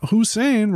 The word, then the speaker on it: Hussein: Yeah, Hussein rhyme with brain.